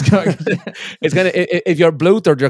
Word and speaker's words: bluthered: Oh, bluthered. – Yeah, bluthered. That's how you say it.